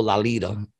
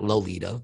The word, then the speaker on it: Lolita: And that's how you say it, people, Lolita. It's like lollipop. I learned that. It's not Lolita, but whatever.